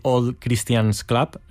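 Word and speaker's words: Old Christians Club: Eduardo, tú eras jugador y cofundador del club de rugby All Christians Club, creo que lo he dicho bien.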